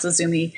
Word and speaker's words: Izumi: Although I did meet his widow, and I do believe it is pronounced Izumi.